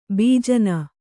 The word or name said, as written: ಭೂಜಾನಿ